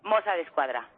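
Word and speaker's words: Mossos d'Esquadra: ¿Mosa de Escuadra? – Mosa de Escuadra.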